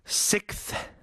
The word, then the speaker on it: sixth: 그리고 sixth. S-I-X-T-H. 여섯 번째. Sixth. 예전에 브루스 윌리스 주연의 영화 제목도 Sixth Sense가 아니라 Sixth Sense라고 이렇게.